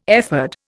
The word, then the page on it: ephod: Indian